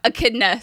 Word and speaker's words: Echidna: Echidna. Sorry, friends. Echidna is how you pronounce it.